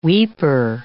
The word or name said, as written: weeper